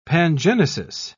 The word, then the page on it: pangenesis: British English